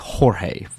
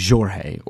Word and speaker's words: Jorge: It is, but you know, the way they pronounce some of the letters, like there's more, you know, like for example, you might get a J in Spanish that comes out like an H, like a H. Sound like Jorge, for example. But in Portuguese, it would be Jorge, or you know, the J is that like in Janeiro, Rio de Janeiro.